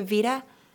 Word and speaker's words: Vita: Well, I'm looking forward to those, and I know I may be crazy in the head, but I'm excited about the Vita coming out. Is that how you say it, the Vita? – Yeah.